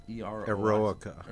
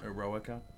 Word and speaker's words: Eroica: Eroica. – Eroica.